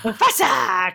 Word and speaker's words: Mufasa: Mufasa, because you have to say it like that.